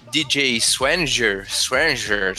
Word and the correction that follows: Swearinger: sei lá como é que fala Swanger, essa porra. É. – Swearinger. É,